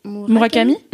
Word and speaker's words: Murakami: Cet été j'ai acheté 2 t-shirts, ils avaient fait une collection avec, c'était Billie Eilish et, je prononce toujours mal son nom, Takashi Moore. – Murakami. – Murakami.